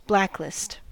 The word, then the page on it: blacklist: American English